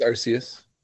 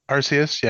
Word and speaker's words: Arceus: I don't know how to pronounce it, but I think it's Arceus. – Arceus, yeah. – I think so.